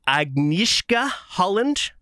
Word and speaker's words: Agnieszka Holland: I'm probably going to mispronounce her name, but Agnieszka Holland, I believe, who is that's pretty good.